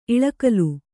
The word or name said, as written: ಇಲಿಗುಲಿ